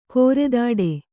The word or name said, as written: ಕೋರೆದಾಡೆ